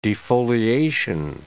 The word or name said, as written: defoliation